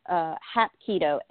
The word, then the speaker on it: Hapkido: Yeah, well, the self-defense class that I started with, Hapkido, Hapkido and Aikido are spelled very similarly, and it's because they have the same roots. There's Hapkido, that's Korean, but I also did Aikido, Aikido, and there are a few differences between them.